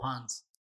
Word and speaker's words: Hans: Like, oh, Hans. The nurse, all right. Like, how do you spell it? H-A-N-S.